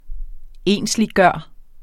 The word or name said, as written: ensliggøre